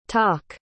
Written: tock

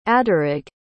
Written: aderrig

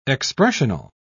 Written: expressional

